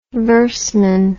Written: verseman

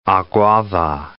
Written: Aguada

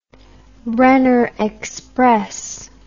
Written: brenner express

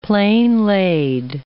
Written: plain-laid

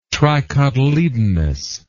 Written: tricotyledonous

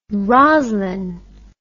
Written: roslyn

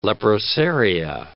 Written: leprosaria

